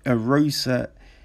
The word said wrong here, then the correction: Arce: he's lost his last three. Okay. So his last fight was against Julio Arosa, Julio Arce, yeah, that's how you say it.